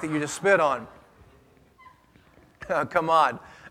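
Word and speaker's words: Chamad (חָמַד): Now that word choice that was translated into English from the Hebrew is the Hebrew word kamad. Or if you want to truly pronounce it like the Hebrews did, you got to have that, like you're clearing your throat. Chamad. Chamad.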